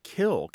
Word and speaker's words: kiln: Kill, K-I-L-N, you say it without the N. – Yeah. – That's an alternate pronunciation, right? – I guess it is. I've heard I'm looking here now in 21 dictionaries, and I did not know that some people say the word kiln without the N. It's considered a valid, acceptable pronunciation.